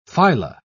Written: phyla

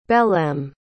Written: belém

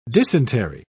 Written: dysentery

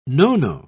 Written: no-no

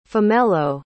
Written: phomello

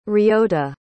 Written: ryota

